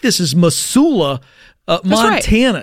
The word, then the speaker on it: Missoula: But also a little bit of it's a hard S, not a soft S. – Missoula. – Correct. – I got it. Missoula, Montana. – It's like a Z.